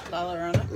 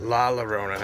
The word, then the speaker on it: La Llorona: Hopefully, uh, El Llorona. – I'll tell you say your name right. La Llorona. – La Llorona. I keep saying El.